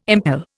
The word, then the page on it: impel: Indian